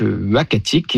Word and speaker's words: Uhackatic: Alors, le nom, ça sera donc Uachatic. Et ce nom-là, donc, il s'écrit U-H-A-C-K-A-T-I-C, nous a été proposé par le conseil des Inuits d'Ekunitchit, qu'on a contacté justement pour avoir leur avis.